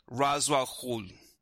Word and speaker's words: Ra's al Ghul: So I went on a linguistic journey to try to educate myself on the proper pronunciation of the word, and apparently we are all wrong. It is like Ra's al Ghul.